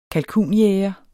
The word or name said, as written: kalkunjæger